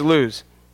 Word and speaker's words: Luz: Though it used to be called Luz, and you pronounced it right there. Luz, it technically is Luz.